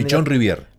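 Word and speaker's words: Pichon-Rivière: Se celebra en homenaje a Enrique Pichón Rivière. Creo que lo dije bien. – Pichón Rivière.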